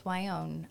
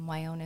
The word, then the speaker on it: Wyonne: It's Justice Wyonne. Wyonne is spelled W-Y-O-N-N-E.